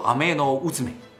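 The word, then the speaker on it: アメノウズメ: 天羽受麦命，读作阿麦诺乌兹麦。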